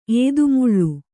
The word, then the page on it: ಏದುಮುಳ್ಳು: ēdumuḷḷu